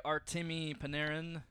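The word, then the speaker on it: Artemi Panarin: Well, hey, Artemy Panarin. Did I say it right? – Yes.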